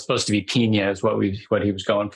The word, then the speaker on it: Pinia: Pinya. I'll drop a link in the show notes. – It's spelled P-I-N-A, right? – P-I-N-I-A.